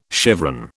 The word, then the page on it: chevron: British English